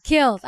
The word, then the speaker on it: Kiehl's: I never know how to pronounce it. – Kiehl's.